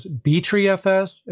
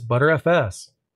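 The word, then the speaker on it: Btrfs: I've actually heard of it pronounced as BtreeFS, as well as BetterFS. And the thing is, these pronunciations are pretty much valid. But considering that I've most often heard of it being pronounced as ButterFS, that's how I pronounce it on my end.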